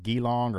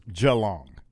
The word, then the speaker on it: Geelong: We had a story last week about, I think we pronounced it Geelong or Geelong. – Yeah, we don't know. – But it's Geelong.